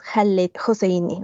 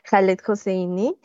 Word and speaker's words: Khaled Hosseini: Y también para que si andan por ahí otros expertos en la obra de Khaled Hosseini, tengo entendido que la pronunciación correcta por ser afgano es Khaled Hosseini, ¿no? O sea, la K no suena, más bien la H como una J, entonces Khaled Hosseini.